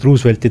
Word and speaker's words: Roosevelt: President Roosevelti teemadel, ta vaatas Roosevelti häältuse peale väga juhmi näoga otsa ja siis küsis, et do you mean Roosevelt? – Roosevelt.